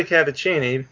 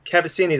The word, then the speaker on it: Cavaccini: Well, it's technically Cavaccini, but Cavaccini is like the Americanized version of it.